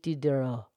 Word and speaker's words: Diderot: And Denis has an S on the end. So some would say Denis. And then Diderot has a T, Diderot. But the French pronunciation, Denis Diderot.